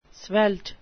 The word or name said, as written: svelte